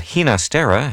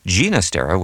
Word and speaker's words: Ginastera: I probably should mention that Ginastera has become the pronunciation you hear most often, but Ginastera with a hard G is probably closer to what Alberto heard at home.